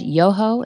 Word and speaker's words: Yoho: I think it's Representative Ted Yoho. Am I saying that correctly? – That's right. So Congressman Yoho is a